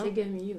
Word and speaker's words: ASEGAMI: Elle coordonne depuis 2006 le groupe de recherche ASEGAMI, est-ce que je prononce bien ? – ASEGAMI, oui.